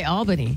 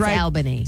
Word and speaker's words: Albany: It's at Albany or Albany. One of those. – And I would say Albany. – Well, she's from that area, so it's Albany.